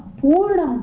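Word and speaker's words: पूर्णम्: It should be sarvesham purnam bhavatu. Purnam. You read it correctly out of habit, I think. So it is not purnam, but purnam. – So here purnam, is long O.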